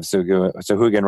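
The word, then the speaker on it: Sahuagin: And now I want to have Suhugan riding sharks and attacking all my ships in the future. – And congratulations on pronouncing it correctly, because it's not the easiest word to say.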